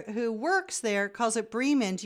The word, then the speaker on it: Bremen: I just wanted to make a gentle comment about the came up about the correct pronunciation of the town Bremen, B-R-E-M-E-N. The fully correct German pronunciation would be Bremen, not Bremen.